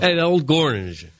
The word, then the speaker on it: Gornicz: How would you pronounce this? G-O-R-N-I-C-Z? – Yeah, I'd just go with Gornich. – Is that what you'd say? – Yeah. – Gornich. – Hit the gore real hard.